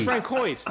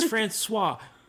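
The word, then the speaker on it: François: It's Frank Quois. No, yeah. Greg. No. It's Francois.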